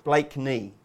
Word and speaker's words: Blakeney: You've got to learn how to say it properly before we can nap it. – Blakely Point. – Blakeney. You say Blakely. – Oh, did I? – That's Blakeney. – Right.